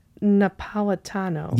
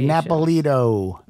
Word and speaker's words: Napolitano: Jeanette Napolitano. – Yes, that's it. And you did a great job of pronouncing that. I would have not been able to do that. – Speaking of pronunciation. – I would have been like, Napolito.